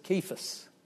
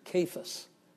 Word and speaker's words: Cephas: We sometimes call him Cephas, but in Greek it's a hard C, a K. He is Cephas, or Cephas. That refers to Peter.